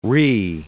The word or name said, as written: Rhee